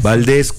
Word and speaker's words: Valdés: Valdés, el mío es Valdés con S y acento en la E.